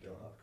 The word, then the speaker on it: Doha: So I know you were deployed to Camp Doha, right? Am I pronouncing that right? – Camp Doha, Kuwait.